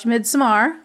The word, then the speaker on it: Midsommar: just watch Midsommar, which is how you say it.